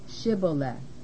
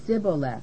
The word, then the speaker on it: Shibboleth: Then said they unto him, Say now Shibboleth. And he said, Sibboleth, for he could not frame to pronounce it right.